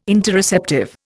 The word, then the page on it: Interoceptive: Indian